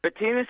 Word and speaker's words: Betinis: Betinis is correct.